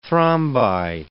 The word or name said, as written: thrombi